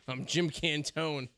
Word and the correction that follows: Cantore: It's Cantore. – Is it Cantore? – It is Cantore.